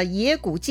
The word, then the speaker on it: 斜谷: 曹操呢，凭着自己的灵感，带着众人逃到了野谷界口。野谷啊是山谷的名字，这个野呢写成倾斜的斜哈。但是念成野啊，这个野谷呢，其实就在今天的陕西省终南山了，已经是汉中的边界了哈。来到野谷口，却看到前面尘土飞扬，哎呀，又有军队来了！